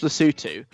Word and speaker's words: Lesotho: But possibly my favourite thing about that whole experience is it's pronounced Lesotho. It's spelt Lesotho.